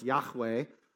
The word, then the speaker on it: Yahweh: Or if you're trying to pronounce it in Hebrew, you have to make that H like a ch, so it's Yahweh. But that word, Yahweh, whenever you see L-O-R-D, L-O-R-D, God is actually naming himself.